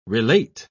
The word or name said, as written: relate